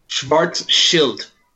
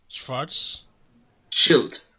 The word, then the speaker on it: Schwarzschild: Schwarzschild. – Schwarzschild. – Schwarz, like black? – Schwarz. – Schild. – Schild. – Not child.